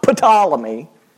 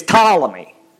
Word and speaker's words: Ptolemy: I know you can't use phonics to pronounce that name. It starts with a P. It's not Ptolemy. It's Ptolemy.